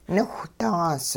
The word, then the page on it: niwhdaas: hupa